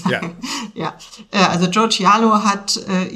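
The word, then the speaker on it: Joe Chialo: zu Joe Cialo. Ich weiß nicht, ist das, mhm. spricht man den wirklich richtig so aus? – Ich würde es auch so sagen.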